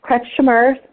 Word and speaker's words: Kretschmer: 2 tablespoons of Kretschmer's, and Kretschmer is spelled K-R-E-T-S-C-H-M-E-R, and I'm going to spell that again for you, K-R-E-T-S-C-H-M-E-R, toasted wheat germ has about 100 milligrams of beneficial omega-3 fatty acids.